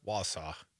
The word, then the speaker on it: Wausau: That's Bull Falls Radio up there in Warsaw. Wausau. I know how you say it.